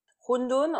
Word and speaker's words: hundun: Rondon, en français, on connaît ça plutôt sous le nom de wonton. Wonton. Rondon, c'est la prononciation à la roi, donc mm. euh, dans le chinois euh, standardisé du nord de, la Chine en fait.